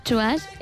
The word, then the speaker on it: TWS: Pues es el grupo Chuas, que se escribe TWS.